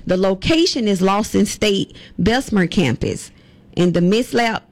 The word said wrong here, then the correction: Milsap: am I saying that right? MILSAP Industrial Training Center.